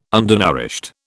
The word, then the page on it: undernourished: British English